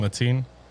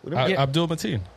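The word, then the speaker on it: Abdul-Mateen: Abdul Mateen. – Abdul Mateen. – Yeah. – Okay. – Abdul, yeah. – Like Abdul Jabbar.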